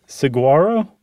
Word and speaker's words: Saguaro: They live in little hollowed out holes of the, how do you say that? Saguaro cacti? – That sounds good.